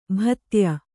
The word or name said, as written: ಭಿದ್ಯ